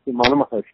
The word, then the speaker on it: Ramana Maharshi: Is that how it's pronounced? Maharshi. – Yes, Ramana Maharshi, yes.